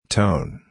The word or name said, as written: tone